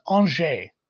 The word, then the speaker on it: Angers: I'm not sure I'd have done a great job without him writing the uh, phonetic spelling, but it is Angers. – Angers. Because, because why the fuck not? Like, like, you just don't pronounce consonants in French.